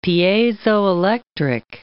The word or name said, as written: piezoelectric